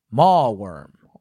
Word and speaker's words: Mawworm: Spell it? What's the spelling? – M-A-W-W-O-R-M. Mallworm.